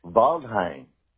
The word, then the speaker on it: Waldheim: Waldheim. – That's how you say it? Is it German? – German, yes. W's are pronounced B's.